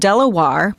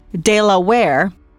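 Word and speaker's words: De La Warr: Some folks pronounce his name Lord Delawarr, others Delaware. After a while, you start to hear Delaware.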